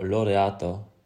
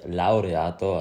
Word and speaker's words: Laureato: Und zwar äh, trägt Simon heute eine Girard Perregaux Laureato beziehungsweise Laureato, also wir wollen das ja hier richtig aussprechen, das ist gleich in Italien.